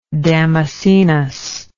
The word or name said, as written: Damascenus